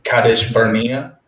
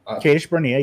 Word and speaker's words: Kadesh Barnea: and forgive me if I don't pronounce this right, but this is how I always say it, Kadesh Barnea. – Kadesh Barnea, yeah. – Kadesh Barnea, Okay. Is fine. There's nothing wrong with that pronunciation.